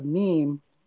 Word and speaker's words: meme: This theory proposes that the meme, it rhymes with dream, like Steve emphasizes,